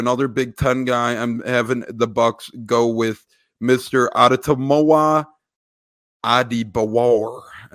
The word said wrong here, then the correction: Adetomiwa: I probably butchered that, but Adetomiwa.